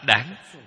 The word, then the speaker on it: đáng: Chỗ này phải nên đọc trại âm, đọc là đáng, chính là cái ý trở ngại.